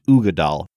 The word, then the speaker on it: Uigeadail: Brad, that's how it's pronounced, Ugadal. I'm going to spell it for our listeners so they see how different the spelling. – You should have spelled it and then pronounced it. – U-I-G-E-A-D-A-I-L. Uigidal.